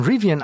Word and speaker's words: Rivian: Wer es verpasst hat, Rivian, Rivian wer oder Rivian, wie wird es richtig ausgesprochen? Rivian. Rivian, ja, das ist jetzt plötzlich der zweitwertvollste Autobauer der USA.